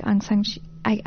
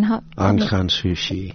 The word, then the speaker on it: Aung San Suu Kyi: So there was a call to duty there that he had to fall, much like Aung San Suu Kyi. I'm terrible pronunciation. – Aung San Suu Kyi. – Thank you.